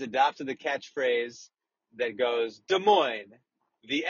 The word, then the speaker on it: Des Moines: And Des Moines is spelled D-E-S-M-O-I-N-E-S. So each of these two words ends in an S, but both of these S's are silent.